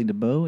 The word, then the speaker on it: DeBaudt: My name is Matthew Kelly DeBow, and you can find that on any social media. – Spell your last name. – My last name is spelled DeBaudt. – DeBaudt. – It looks like Debot.